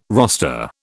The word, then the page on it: roster: British English